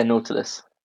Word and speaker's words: Nautilus: So your company's called Nautilus. I'm pronouncing that right, aren't I? – Yeah, Nautilus.